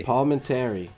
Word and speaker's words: Palminteri: How do you say that name? – Palminteri. – That eye is silent.